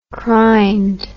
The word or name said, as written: crined